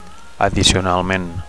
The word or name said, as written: addicionalment